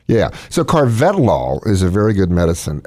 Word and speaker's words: Carvedilol: CARVEDILOL. 6 milligrams. – Looks like Carvedilol. – Carvedilol? – Carvedilol.